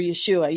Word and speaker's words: Yeshua: So just before I even say anything, clarify, we both agree that salvation comes from Yeshua only. After that, then we learn how to walk in his ways, but we cannot be saved through keeping the Torah perfectly. We can only be saved through Yeshua.